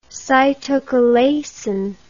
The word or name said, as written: cytochalasin